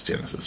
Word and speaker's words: Genisys: No, it's Genesis.